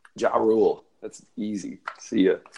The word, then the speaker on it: Ja Rule: Ja Rule. Did I say it right? – Yep, he's gone.